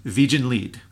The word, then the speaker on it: Wiegenlied: The name of the song is Wiegenlied, which uh-huh. I believe is how the Germans would pronounce it, right? – Not Wiegenlied?